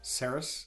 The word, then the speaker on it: Ceres: C-E-R-E-S. Ceres. I mean, is that how you pronounce it, Grex? – Sure. – I believe so. – Ceres?